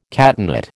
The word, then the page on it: catenate: American English